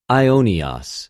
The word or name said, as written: αἰωνιος